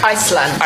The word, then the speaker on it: Iceland: Iceland.